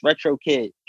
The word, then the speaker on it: Retro Kiq: So a lot of people when they pronounce my name, even when performing, Retro Kiq, I have to get on a microphone. Y'all, it's Retro Kiq.